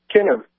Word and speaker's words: Kinev: Kinnev, K-I-N-E-V.